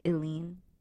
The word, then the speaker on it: Ilene: There's a name that's spelled I-L-E-N-E. – Yeah. – That's like Eileen.